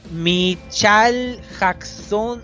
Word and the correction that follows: Michael Jackson: Ah, Michael Jackson.